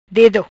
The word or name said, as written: Dedo